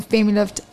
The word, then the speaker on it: FemiLift: Is the FemiLift, is that right? – Did I say that right? Yeah. Okay, so what is the difference between the FemiLift and the O shot?